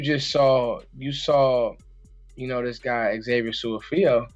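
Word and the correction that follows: Su'a-Filo: Su, Su, how do you say his name, Joe? Suafilo. Suafilo. Suafilo.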